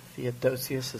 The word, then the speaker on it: Theodosius: Theodosius, is that a correct pronunciation? – Mm-hmm.